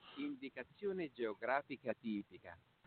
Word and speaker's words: Indicazione geografica tipica: And let's see if I can get this on the air, how to pronounce it. Hello, Google. – Indicazione geografica tipica. – Yeah, hold on just a second. Let me turn that up a little bit. – Indicazione geografica tipica.